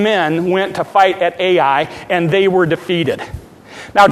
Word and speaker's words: Ai: They call it, that's how you say it, Ai.